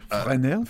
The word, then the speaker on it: Fresnel: I don't think you pronounce the S, would you? Fresnel.